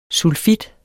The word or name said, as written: sulfit